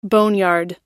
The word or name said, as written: boneyard